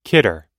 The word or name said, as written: kidder